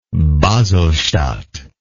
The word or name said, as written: baselstadt